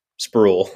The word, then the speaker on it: Spruill: Uh, So it sounds like spool, but it's just sproul with an R.